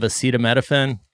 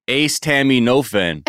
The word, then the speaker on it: acetaminophen: Wait, that's how you say it? What is it? – Acetaminophen? – I love Kirkland brand. – I thought it was Ace Tammy Nofen.